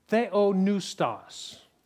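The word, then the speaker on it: theopneustos: That word is the Greek word theonoustos. Theos means God. Noustos starts with a P, it's that silent P.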